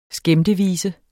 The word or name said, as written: skæmtevise